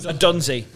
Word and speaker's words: Odunze: Odunze.